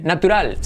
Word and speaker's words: natural: Anyways, 69, natural. Se escribe igual, pero fíjate cómo se dice natural. Natural. Natural. Letra clave R, natural. Y letra clave L al final.